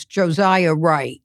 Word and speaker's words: Josiah Wright: So one young man that I was rooting for, and remain hopeful for is Josiah Wright.